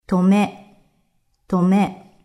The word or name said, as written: Tome